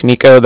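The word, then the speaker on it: Niko: So it's Nico. My name's Nico. That's N-I-K-O. – Mm-hmm. – And then the Bank of Cardiff is spelled C-A-R-D-I-F-F, as in Frank Frank.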